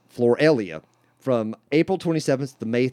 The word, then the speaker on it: Floralia: Roman Catholic traditions of crowning statues of Mary with flowers on May 1st have Roman pagan roots from that Floralia, that's how it's pronounced, Floralia tradition before Christianity and Roman Catholic traditions were formed.